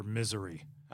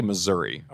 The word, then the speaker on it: Missouri: When it comes to the pronunciation of Missouri, is it Missouri or Misery? – I say Missouri.